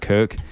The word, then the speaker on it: Koch: It's pronounced Koch.